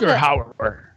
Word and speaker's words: Rutger Hauer: On Smallville, Morgan Edge was first played by Ruder. How do you say his name? Is it Rutger? Rutger Hauer? Okay. I'm going to restart. – On Smallville, Rutger Hauer.